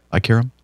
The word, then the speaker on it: Ein Kerem: E-I-N. – Yep. – K-E-R-E-M. – I care him.